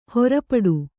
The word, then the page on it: ಹರಿಪೀಠ: hari pīṭha